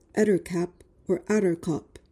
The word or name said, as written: ettercap